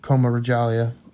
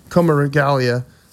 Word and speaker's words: Coma Regalia: So Coma Regalia is how it's pronounced, not Coma Regalia, which I thought.